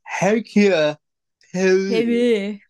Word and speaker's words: Hercule Poirot: It's pronounced Hercule Poirot. – Hercules Poirot, I believe is how you pronounce it.